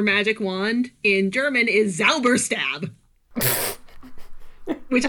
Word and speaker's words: Zauberstab: Just, I'm going to pronounce it just because I love that in the German language, the Z has the TS sound. It's Zauberstab.